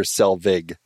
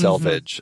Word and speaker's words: Selvig: Selvig, not mm-hmm. Selvage.